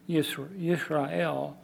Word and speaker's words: Yisrael: Interestingly enough, the very name Israel, pronounced Yisrael in Hebrew,